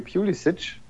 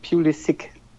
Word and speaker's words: Pulisic: Und dann habe ich gesagt, ja, wir nennen den hier Pulisic, weil wir eben, sage ich mal, von Luka Modric oder Kovacic oder wem auch immer, diese Endung halt so mit diesem Itch aussprechen. Und da sagte er, ja, interessant, okay, wir sagen in Amerika nämlich Pulisic. Und das ist wohl auch die Aussprache, die er selber favorisiert.